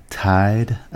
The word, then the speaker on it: Tiede: Vicky Tiede, I believe is how you say her last name, T-I-E-D-E, is the author.